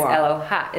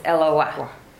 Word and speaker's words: Eloah: Because you might think it's Eloha. It's Eloah. It's Eloah. Because it has, and it's a little short, because of the dot, right? So that's how it's pronounced, Eloah.